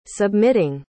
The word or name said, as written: submitting